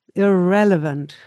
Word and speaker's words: irrelevant: You need the stress and the beat goes on the second syllable here. You have a pre-syllable, the ear, irrelevant. And then it sounds okay.